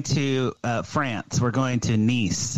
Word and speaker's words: Nice: It's Nice. It's spelled like nice.